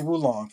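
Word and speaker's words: Wo Long: Is it Wolong or Wulong? – I think it's actually Wulong, from what I've heard.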